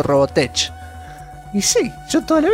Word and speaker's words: Robotech: Al menos ya sé que si se me aparece el gaucho oscuro, le tengo que rezar a Robotech.